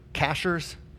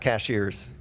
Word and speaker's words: Cashiers: It's apparently fairly lucrative, because we were in Cashers. And that's how you say it. It's not cashiers. If you ever go out there, it's cashers.